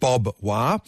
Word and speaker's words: barbed wire: As they pronounce it, Bob Wire.